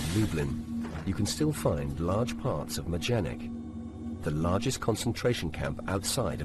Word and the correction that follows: Majdanek: The announcer repeatedly mispronounces the name of the camp, Majdanek, as...